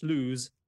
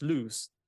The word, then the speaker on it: lose: I used to pronounce lose as loose, which is a different word.